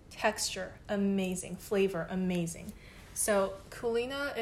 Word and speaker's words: Culina: And I want to talk about Kulina. It's spelled C-U-L-I-N-A,